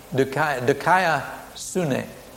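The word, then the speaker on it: Dikaiosune (δικαιοσύνη): Martin Luther discovered this critical error only by studying the original Greek and finding the meaning of diakiosune. How do you pronounce that? – Dikaiosune.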